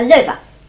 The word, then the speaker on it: Lowy: We used to call her Lover. – That's how you pronounce it, is it? I didn't know that. Well, it changed during the war. – I see. – But it was Lover, L-O-W-E, with...